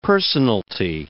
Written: personalty

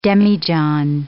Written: demijohn

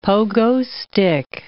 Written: pogo stick

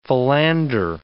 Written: philander

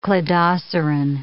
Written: cladoceran

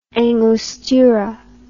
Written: angostura

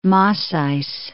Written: Massys

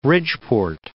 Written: Bridgeport

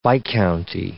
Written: viscounty

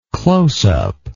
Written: closeup

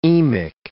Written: emic

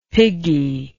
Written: piggie

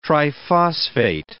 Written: triphosphate

